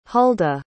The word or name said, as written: huldah